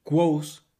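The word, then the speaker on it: Kvothe: Narra la historia de Quo's que cuando el nombre se lee se escribe totalmente diferente a como yo lo acabo de pronunciar, pero bueno, así es como se pronuncia, Quos.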